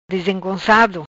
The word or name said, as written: Dizengoncado